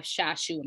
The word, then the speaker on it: Shashu: And we need to be the ones to hear it so I'm so blessed today to have Shashu.